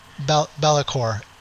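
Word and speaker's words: Be'lakor: So, coming in first place was the, how do you pronounce it? Bellacor the Dark Master? – Bellacor, yeah.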